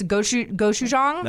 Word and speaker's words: gochujang: yeah, and it has this gochujang.